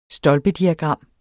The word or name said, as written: stolpediagram